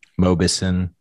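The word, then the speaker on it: Mauboussin: Mobison.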